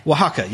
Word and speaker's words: Oaxaca: Oaxaca. – Oaxaca, yes, thank you.